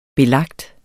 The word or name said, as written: belagt